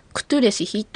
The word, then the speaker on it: クトゥレシヒ: はい、テキストを持っている方は長い表現の場合はトゥレシ、シは小さい表現になっていますけれども、クトゥレシヒと発音する場合はしっかりとシを発音してください。